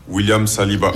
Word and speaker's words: William Saliba: William Saliba.